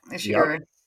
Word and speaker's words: Ishiguro: by Kazuo Ishiguro? – Yeah, Ishiguro.